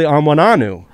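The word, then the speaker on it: Onwenu: They were like chuckling to themselves, like, it's actually Amwananu.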